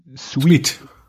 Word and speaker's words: Suite: Und dann sagt er, die Aussprache von Anzug, Suit, aber das ist ja nicht S-U-I-T, sondern S-U-I-T-E, also Sweet. Sweet.